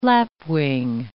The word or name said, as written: lapwing